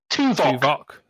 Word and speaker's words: Tuvok: His name sounds a bit like Spock. Tuvok. – Spock, yeah. – Rhymes with Spock. Man, that's a gimme, isn't it? – Yeah.